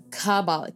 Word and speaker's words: Kabbalah: Okay, so it is an ancient form of magic called Kabbalah. And I hope I said that correctly. – Kabbalah. – K-A-B-B-A-L-A-H.